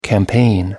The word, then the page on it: campaign: American English